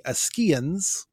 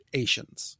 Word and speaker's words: Achaeans: He says that the Greek pronunciation would be Ascheans. And I guess I'm in the doghouse again because I've always reflexively pronounced it Asians.